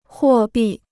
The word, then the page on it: 货币: huò bì